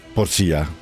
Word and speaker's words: Porcia: Porcia, sì, l'accento va diverso. – Porcia, detto bene? – È giusto, giusto, giusto.